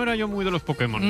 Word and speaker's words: Pokémon: Para permitir que Putemón. – El poder que hay en Pokémon. – Pues hazte con todos, Pudemon. – Te diré que es difícil decirlo peor.